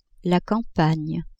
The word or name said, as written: campagne